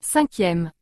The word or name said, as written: 5e